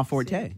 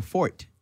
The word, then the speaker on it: forte: That's not my forte. – No. – Or fort, as I pronounced it fort? Up until a year ago. I didn't know the little accent.